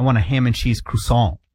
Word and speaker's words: croissant: Is it croissant? – Croissant. – Oh. – But that's not the proper pronunciation.